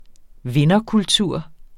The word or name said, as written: vinderkultur